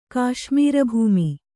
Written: ಕಾಶ್ಮೀರಭೂಮಿ

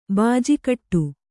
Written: ಬೂಜುಗಟ್ಟು

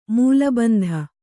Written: ಮೂಲಬಂಧ

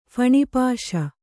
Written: ಫಣಿಪಾಶ

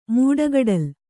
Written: ಮೂಡಗಡಲ್

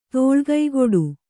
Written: ತೋಳ್ಕೈಗೊಡು

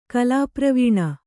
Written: ಕಲಾಪ್ರವೀಣ

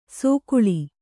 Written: ಸೊಗುಳಿ

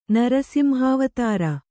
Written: ನರಸಿಂಹಾವತಾರ

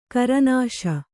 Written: ಖುರ್ನೀಷ್

